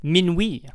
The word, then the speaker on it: Minuit: book The Island at the Center of the World to point out that his name was probably pronounced Minuit because it would have been pronounced the French way.